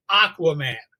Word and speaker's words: Aquaman: And I said, Acua Man. He said, how do you spell that? And I went, A-Q-U-A. And he said, no, that's Aquaman.